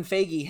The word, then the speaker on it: Feige: And um, Kevin Feige had did I pronounce his name correctly? – Feige.